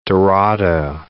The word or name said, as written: dorado